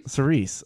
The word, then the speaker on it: Cerise: Sir Rice. How do you say it? – Cerise. – I always call it Sir Rice. I don't know. Fuck me. Cerise.